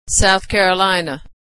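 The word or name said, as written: South Carolina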